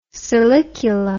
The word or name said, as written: silicula